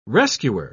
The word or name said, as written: rescuer